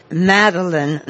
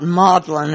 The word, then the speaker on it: Magdalene: It's called, the restaurant, Madeline, not Maudlin, as I was taught to pronounce Madeline in English.